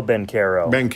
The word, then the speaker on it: Banchero: Bucera? Or yeah, Paolo Banchero. – Banchero and A.J.